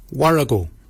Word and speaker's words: worragol: Bakin is spelled B-A-G-I-N-Y. Bakin. Bakin is your beard. The upper mouth, we say warago. Warago. Warago is spelled W-O-R-R-A-G-O-L.